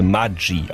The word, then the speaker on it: Maggi: Eigentlich müsste das Ganze Maggi ausgesprochen werden, italienisch wie Formaggio. Denn Julius Maggi, Maggi, ist zwar in der Schweiz geboren, aber als Kind eines italienischen Einwanderers. Und tatsächlich heißt Maggi in der Schweiz Maggi.